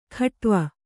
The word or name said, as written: ಖಟ್ವ